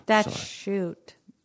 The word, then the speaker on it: statute: You mean the statue of limitations? – Statute. – I know you said it. You said it correctly. I said it incorrectly.